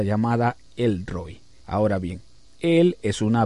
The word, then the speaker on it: Roi: Así que cuando usted dice El Roi, es una palabra que está deletreada en una forma poco corriente. Se pronuncia Roi.